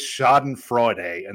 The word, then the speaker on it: Schadenfreude: I enjoyed some serious Schadenfreude, and that's how you say it.